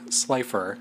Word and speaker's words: Slipher: Slipher. – Spell it. – S-L-I-P-H-E-R.